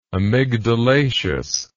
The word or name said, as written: amygdalaceous